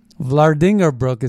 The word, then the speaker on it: Vlaardingerbroek: Vlaardingerbroek is how you say it, but um, it's spelled V L A A R D I N G E R B R O E K.